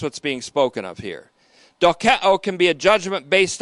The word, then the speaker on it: dokeo: The kind of thinking that's being warned against in Hebrews 4:1 is called dokeo. D-O-K-E-O. D-O-K-E-O. Omicron O, then Omega O. Long O. Dokeo.